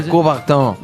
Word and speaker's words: Coubertin: El Barón de Coubertin.